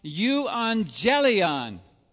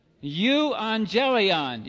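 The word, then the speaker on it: Euangelion (εὐαγγέλιον): You is how that's pronounced. Euangelion. Would you like to say that? Euangelion.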